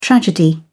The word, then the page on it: tragedy: British English